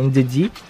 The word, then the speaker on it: Ndidi: Desarma bem, é o volante que mais desarma no campeonato, jogador que mais desarma, que é o Ndidi.